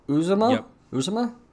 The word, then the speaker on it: Uzomah: TJ Uzomah? – Bengals tight end.